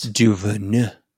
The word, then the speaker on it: DuVernay: I can never pronounce DuVernay.